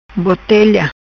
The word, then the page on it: Botelha: patua